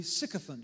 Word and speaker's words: sycophant: Some people pronounce it sycophant, but it is actually, sycophant. Just remember, sick people are sycophants. S-Y-C-O-P-H-A-N-T.